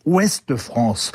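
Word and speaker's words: Ouest-France: se prononcent en réalité « Ouest France » avec un « e » à la fin d'Ouest parce que l'âpreté des côtes granitiques, la brutalité des vents marins, la vitesse des vies en mer n'interdisent pas une certaine féminité.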